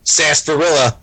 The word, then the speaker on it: sarsaparilla: That seems like right around the time when they, you know, invented, quote, milkshakes, and I'm going to say it, sarsaparilla. I said it right.